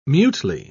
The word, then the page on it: mutely: British English